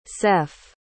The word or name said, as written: seff